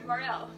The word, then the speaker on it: morel: But yeah, sure, Morel. It rhymes with farewell.